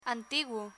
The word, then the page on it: antiguo: spanish